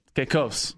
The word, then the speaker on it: Caicos: Well, as you know, I just got back on Saturday from Turks and Caicos. – Caicos. – That's it. Caicos.